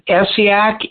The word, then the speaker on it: Essiac: It's spelled Essiac, E-S-S-I-A-C, the blend. And a good way to remember it is just the name Casey. Her name was Renee Casey. So it's her name right. – and her last name spelled backwards. – Okay. And Casey was C-A-S-I-E? – C-A-I-S-S-E.